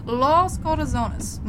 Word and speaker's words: Los Corazones: Los Calzones. – Los Corazones, Molly. It's Spanish. It means the hearts.